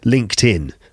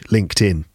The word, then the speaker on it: LinkedIn: LinkedIn. That's how we pronounce that website in English, LinkedIn. I wonder how you pronounce LinkedIn in your language. For example, in French, it's LinkedIn, I think. Anyway, LinkedIn is how we pronounce it. LinkedIn.